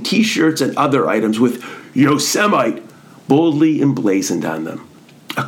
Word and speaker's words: Yosemite: Rather than reading the park's name as Yosemite, and frankly to me, it doesn't look like it should be pronounced that way, he pronounced it as Yosemite.